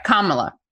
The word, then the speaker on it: Kamala: I think it's Kamala. – All right, Kamala.